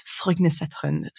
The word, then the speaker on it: Frognerseteren: It's Frognerseteren. So um, it's up in the hills. It's spelled sort of like F R O G N E R uh, that's the place, Frogner, and then Seteren, S E T E R E N.